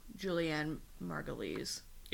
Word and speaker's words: Julianne Margulies: Or should I say Julianne Margulies? – Yes.